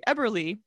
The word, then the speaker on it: Eberle: The ghost stories surrounding this building didn't start until the 1980s when Gary Eberle, we mentioned him previously on the podcast and totally mispronounced his name. – Yep. I was like, that's not what we said. Whoops. – Very sorry, sir. – Sorry, sir. – Eberle.